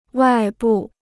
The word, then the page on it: 外部: wài bù